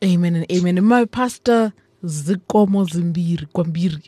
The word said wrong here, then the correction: Zikomo Kwambiri: Is that it correct? – Zikomo Kwambiri. – Yes!